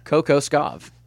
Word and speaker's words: Kokoskov: It's Igor Kokosovic. – There's not an ick at the end. – Spell it one more time. – K-O-K-O.